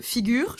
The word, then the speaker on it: Figure: Bonjour Virgile, aujourd'hui on reçoit donc le CEO de Figure. Je le prononce comme il faut, je le dis pas à l'anglaise. – On le dit à la française.